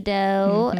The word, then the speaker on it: Deux: The podcast was selected to be a brand ambassador for a brand of edible cookie dough. Mm-hmm. And I mean, you can make it into normal cookies by baking them too, but it's called Dough. – Mm-hmm. – It's spelled D-E-U-X.